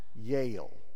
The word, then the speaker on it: Yale: About that time, Frank Sr., who had parked the car, was sauntering in, and he said, "Daisy, Daisy, it's pronounced Yale."